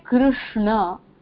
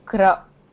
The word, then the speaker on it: Krishna: So here, if I say Krishna, you think if it is ikka plus ra, if it is ikka plus ra, you would be pronouncing it like kra. – Okay. – You are not saying Krishna.